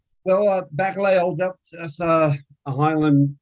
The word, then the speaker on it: Bacalhau: Moving ahead here, you your next station was Bacalhau Island. Am I pronouncing that right? – Yes, Bacalhau. More or less, which I think comes from Portuguese for cod, right? – It's a Portuguese name, yes.